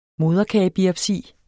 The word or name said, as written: moderkagebiopsi